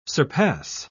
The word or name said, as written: surpass